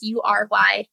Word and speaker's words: Ury: It's Ury. But essentially it is, how do you say no, but you're using no to get what you want? – And to something that's beneficial for all.